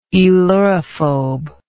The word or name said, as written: aelurophobic